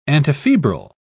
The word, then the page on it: antifebrile: British English